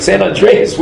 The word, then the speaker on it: San Andreas: San Andreas.